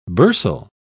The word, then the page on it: bursal: British English